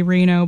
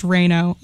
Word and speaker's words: Reno: Last name, R-E-N-O. It's spelled like the city, Reno, but it's pronounced Reno.